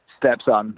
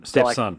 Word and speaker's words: Stepson: But just talking about you guys again, is it Stepson? Is that how you pronounce the name of the band? – Stepson. – Stepson. – So like your Stepson kind of.